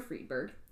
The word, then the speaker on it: Friedberg: Usually you say the second vowel. – Okay. Near Friedberg, with his wife and children, including his stepdaughter, Priscilla Ann.